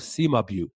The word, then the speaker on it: Cimabue: Cimabue. That name is spelled C-I-M-A-B-U-E. Cimabue, C-I-M-A-B-U-E.